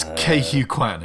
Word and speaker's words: Ke Huy Quan: It's Ke Huy Quan, isn't it? – Yes, Quan.